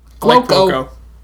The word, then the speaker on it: Cuoco: The show stars in its original configuration Johnny Galecki, Jim Parsons, Kelly Cuoco? I don't know how to say her name, actually. Is it Cuoco? – Cuoco.